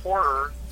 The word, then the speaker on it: horror: It's, it's horror with two syllables.